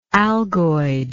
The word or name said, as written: algoid